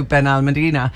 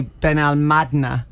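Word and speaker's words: Benalmádena: Yeah, so do I, but it's actually Ben Almadna. If you're the stress is on the N at the A.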